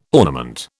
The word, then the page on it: ornament: British English